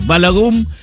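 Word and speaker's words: Balogun: Llamando a la FIFA para decir que estaba mal echado el jugador Bogalum, ¿cómo es? Ahí te lo digo bien. Y se empezó a pudrir todo a partir de eso, porque la FIFA dijo que sí. Balagum fue expulsado y Folarin Balagum, opa, va a jugar hoy contra Bélgica en Seattle.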